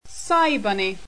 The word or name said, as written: cybunny